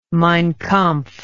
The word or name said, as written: mein kampf